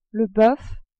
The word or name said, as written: boeuf